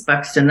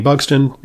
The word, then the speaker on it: Buxton: Uh, Jayne Rees Buxton. That's my middle name. R E E S is the middle name. Yeah. – Okay, and just you know, if you Google Jayne Buxton, it's spelled B U X T O N.